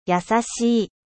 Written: doux

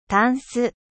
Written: commode

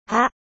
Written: feuille

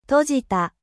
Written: ferme

